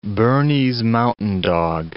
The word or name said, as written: Bernese mountain dog